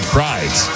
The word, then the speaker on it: Prydz: Is it Eric Prydz? – Yeah, but it's Prydz. – P-R-Y-D-S.